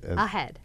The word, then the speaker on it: AHEAD: And you pronounce it as? – AHEAD. – AHEAD. Yes. Okay.